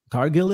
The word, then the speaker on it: Cargill: How do we say her last name? Cargill? – Cargill? Yeah. – Cargill it is for, you know, so want to talk about that.